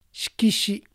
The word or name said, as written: Shikishi